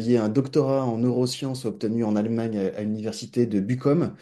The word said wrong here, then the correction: Bochum: Bochum. – Oui, c'est ce que j'allais dire, je prononce très probablement mal.